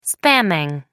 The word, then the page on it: spamming: American English